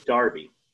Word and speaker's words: Derby: You know, the people, I would say people in Connecticut don't, you know, there's Derby that's now pronounced as Darby in Connecticut.